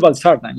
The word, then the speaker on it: valsartan: Regarding your article about, and I'm probably gonna pronounce this wrong, sacubitril and valsartan, these two Correct. – Drugs. Can you pronounce them, please? – Yes, sacubitril valsartan.